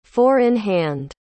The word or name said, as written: four-in-hand